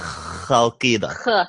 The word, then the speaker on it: Chalkida: See, it's C-H, yes. But they say it like a Chalkida.